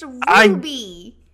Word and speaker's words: Ruby: We can tell that Rudy gets more love than Daisy. – Ruby. – Sorry. – Rudy, come on. It rhymes with Zuby. It's Ruby.